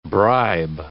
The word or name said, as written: bribe